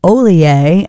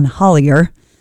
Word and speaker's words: Ollier: Digging on that, some new music from a new artist on these airwaves, John Ollier and The Reverie. Looks like John Hollier, but some French roots, so it is pronounced Ollier.